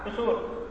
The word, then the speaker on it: tsuwr: இது எப்படி ப்ரொனவுன்ஸ் பண்ணோம் அப்படின்னாக்கா டிசூர் டிசூர்